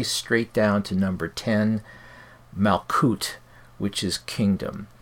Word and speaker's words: Malkuth: Note that all of those words that contain the TH, I'm not pronouncing it as th, but as t. And this is just a matter of preference. Some people say Malkuth and Kether and Tifereth, etc.